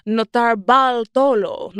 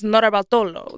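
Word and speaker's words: Notarbartolo: Leonardo Notarbaltolo. No, so I did say it wrong. It's Notarbaltolo.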